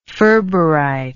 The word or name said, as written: ferberite